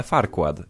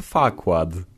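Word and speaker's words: Farquaad: Po polsku wymawiane Farquad. I tak się pisało, Farquad. Lord Farquad. Ale po angielsku, kiedy on się przedstawiał, on nie wymawiał RR, było nieme. On się przedstawiał jako Lord Fakład.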